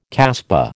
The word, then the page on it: kasbah: American English